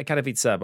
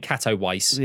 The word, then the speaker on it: Katowice: Is that how you pronounce it? – Yeah, Katowice, but I think, yeah, that's fine. Better than Katowice.